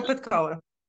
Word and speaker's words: Svetla Petkova: Svetla Petkova.